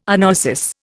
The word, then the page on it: anoesis: Indian